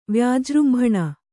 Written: ವ್ಯಾಜೃಂಭಣ

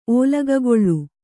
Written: ಓಲಗಗೊಳ್ಳು

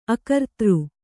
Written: ಅಕರ್ತಾರ

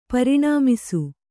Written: ಪೂರ್ಣಮಾಸ

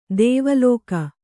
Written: ದೇವಲೋಕ

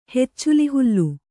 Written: ಹೆಚ್ಚುಲಿಹುಲ್ಲು

